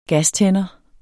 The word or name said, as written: gastænder